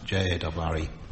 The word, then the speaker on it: Jarre: I think he's I don't think he has an accent on the end of his name. Maurice Jarre. Yes, that's right. Jarre.